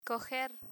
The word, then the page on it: coger: spanish